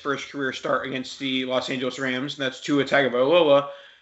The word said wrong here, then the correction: Tagovailoa: I learned recently that it's supposed to be Tunga Vailoa. There's definitely an N in there somehow.